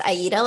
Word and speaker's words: Aida: I'll spell it for you because Aida is kind of a rare name, but it's Aida like the Italian opera.